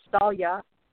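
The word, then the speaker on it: Saille: Sail or Irish pronunciation is Salia, the willow tree.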